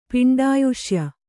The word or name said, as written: ಪಿಂಡಾಯುಷ್ಯ